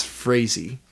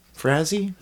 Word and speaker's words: Frazee: So he would, of course, be wrong, but with his brash confidence, I'm pretty sure it's Frazee. Frazee? – Cool. How do you spell it? – F-R-A-Z-E-E.